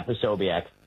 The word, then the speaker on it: Posobiec: Yeah, Posobiec.